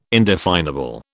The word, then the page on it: indefinable: British English